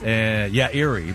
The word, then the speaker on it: Eyrie: The name of an eagle's nest is Eyrie. – Oh. Eyrie. – Yes. – Eyrie, but it's spelled E-Y-R-I-E.